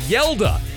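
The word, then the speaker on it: Hjalte: Arkansas has a lineman whose name is spelled H-J-E-L-T-E, pronounced Yelda.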